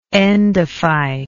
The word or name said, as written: endophytous